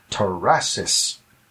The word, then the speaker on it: tarassis: The word is tarasis. That is spelled T-A-R-A. What was that? – Mm-hmm. – Okay. T-A-R-A-S-S-I-S. Tarasis.